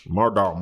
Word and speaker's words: Murdaugh: I found the documentary, the Mardar Mo- Mordar family, the Modar murders. Mordar. – I don't think you said that right once. – Mardar. – That was five tries. – Murdaugh Murders.